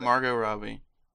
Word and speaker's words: Margot Robbie: Margot Robbie.